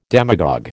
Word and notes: demagogue: American English

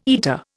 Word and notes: eater: Indian

